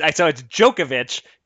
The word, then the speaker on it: Djokovic: It's Djokovic, right? Djokovic. I mispronounced it because I've got Jokic in my head.